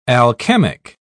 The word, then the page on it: alchemic: British English